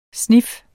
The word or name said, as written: snif